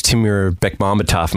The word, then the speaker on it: Timur Bekmambetov: Yeah, one of those Timur Bekmambetov movies.